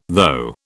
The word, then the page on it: though: British English